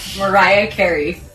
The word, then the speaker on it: Mariah Carey: Bueno, descobrim-ho, ho hauríem de pronunciar així. – Mariah Carey. – Repetim? – Com som? – Mariah Carey. – Ara bé, Mariah Carey. – Mariah Carey. – No, Carrie no.